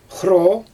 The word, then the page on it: xroo: Xyzyl